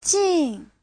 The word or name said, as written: jin4